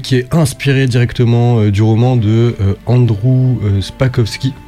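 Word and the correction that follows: Sapkowski: J'espère que je prononce bien. – Sapowski. Andrzej Sapowski.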